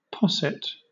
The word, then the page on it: posset: British English